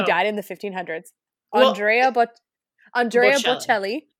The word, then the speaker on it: Bocelli: Not Andre Botticelli. No, is that the chef? It's Bocelli? Bocelli? – Okay, slow down.